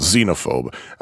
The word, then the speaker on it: Xenophobe: Uh, and that's how it's pronounced, by the way. It's not pronounced xenophobe. I, I personally asked Brian once. I said, "Dude, is it supposed to be pronounced xenophobe or xenophobe?" He said xenophobe.